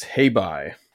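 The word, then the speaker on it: Hei Bai: At least I go with the way they pronounce it in the episode. It's hey bye. – Hey bye, that's it.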